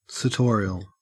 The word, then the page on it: sartorial: Australian English